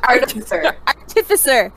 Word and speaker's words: artificer: Artificer. – Artificer.